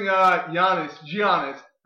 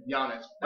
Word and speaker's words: Giannis: By the way, you're pronouncing his name Kawhi. And I'm pronouncing Giannis. I think we're both fucking that up. – Could be. – I think it's Kawhi and Giannis, as you said.